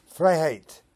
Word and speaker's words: Freiheit: Okay, I just learned through YouTube that that is pronounced Freiheit, or Freedom.